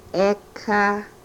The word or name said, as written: ekah